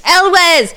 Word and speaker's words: Elwes: That whole conversation popped into my head as soon as I wrote his name, like, how do you pronounce that? Is it Elwes or is it Yules?